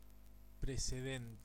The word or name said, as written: preceding